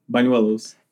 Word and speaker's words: Bañuelos: We're joined by the one and only Eduardo, aka Eddie Bañuelos.